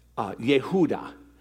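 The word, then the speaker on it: Yehuda (יהודה): This name, Judah, is pronounced Yehuda in Hebrew.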